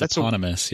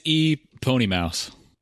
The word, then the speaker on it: eponymous: I believe that's eponymous. Yeah. – God damn it. That's the only word. – Well, it's E Pony Mouse.